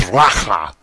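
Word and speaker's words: raca: If you call your brother a fool, the Hebrew word there is pronounced racha.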